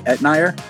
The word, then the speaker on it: Etnier: And for listeners, this doctor, I'll have links below to your books below, is Dr. Jenny, and I just want to make sure I pronounce this correctly, Etnier? – That's correct. You got it.